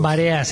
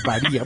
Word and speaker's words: varía: Varea, se dice varea. – No, no se dice varea, no digan varea, se dice varía.